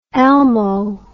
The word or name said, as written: elmo